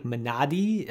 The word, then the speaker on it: Minari: I know that the proper pronunciation of Minari is, I think, Minadi, I think is closer to the way that you would pronounce it, but my American tongue is just not going to make that work.